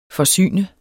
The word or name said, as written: forsyn